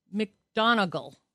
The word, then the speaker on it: McGonigal: Also coming up on today's show, we're going to be talking a little bit about Charles McGonigal. Let me see if I'm saying his name right. Yes, McGonigal, Charles F.